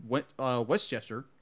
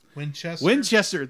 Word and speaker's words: Winchester: who's placed by Dean, who's voiced by Dean Westchester. – Winchester.